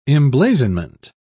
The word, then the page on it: emblazonment: British English